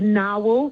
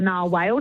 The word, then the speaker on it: narwhal: The narwhal, okay, so the narwhal, which is an animal, the narwhal or the narwhale, depending on how you say it.